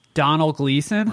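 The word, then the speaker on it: Domhnall Gleeson: Donald Gleason.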